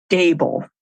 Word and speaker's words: Dabel: I'll spell it as my mom always does. It's D as in dog, A, B as in boy, E, L. And it's Dabel.